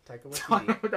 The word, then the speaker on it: Taika Waititi: Taika Waititi? – Taika Waititi. I was gonna say it so wrong. Taika Waititi? – Yeah, Taika Waititi. – That did not sound... – Taika Waititi.